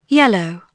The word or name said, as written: YELL